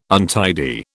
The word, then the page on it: untidy: British English